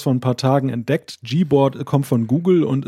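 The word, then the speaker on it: Gboard: ja, höchstens, wenn man sich ein bisschen dumm anstellt, kann man es falsch aussprechen, aber äh, allgemein äh, ist, glaube ich, üblich die Aussprache G-Board.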